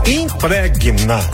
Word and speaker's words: impregna: Mas é isso mesmo, impregna, G-N-A, não bota o I, U, I não.